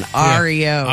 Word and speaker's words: Oreo: That's a St. Louis pronunciation. Ario.